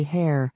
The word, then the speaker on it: Hare: His name is Jerry Hare, which quite appropriately rhymes with derriere, because the guy is a pain in that precise portion of the anatomy.